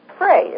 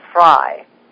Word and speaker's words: Frey: The strange and wonderful story of Annette Martin, a biography written by James Frey. It's not an autobiography. – No, it's a biography by James N. Frey. – Frey, sorry, I mispronounced that.